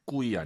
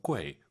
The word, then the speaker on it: 贵: 꾸이 아니고 UI 사이에 넣어서 꾀, 꾀是꾀 이렇게 발음하시고요.